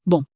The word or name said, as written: bon